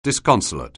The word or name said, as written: disconsolate